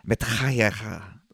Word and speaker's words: Mattithiah: Matahaina. – Shit. – How did you not know that? Shema. – I don't think you're pronouncing that right. – I think that was perfect. M-A-T-T-I-T-H-I-A-H. Matahaina.